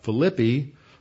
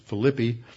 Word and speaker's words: Philippi: which is the main harbor serving Philippi, as it's pronounced in English, but the Greeks pronounce it Philippi.